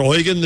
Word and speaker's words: Eugen: Is it Eugen the German way or Eugene? He said, Eugen's right, but I go by, I kind of give up and I take Eugene.